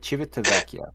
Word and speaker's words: Civitavecchia: Civit Civitavecchia, which is basically the port of Rome.